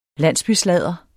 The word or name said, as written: landsbysladder